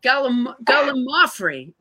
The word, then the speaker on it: gallimaufry: And somebody wrote in and said, you know, that's not how it's pronounced. Oh. So all these weeks, I've thought I was educating the public in this fun way. And it turns out I was telling them something totally incorrect. Gallimaufry, which is almost impossible for me to say it the correct way.